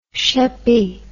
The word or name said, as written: sheppey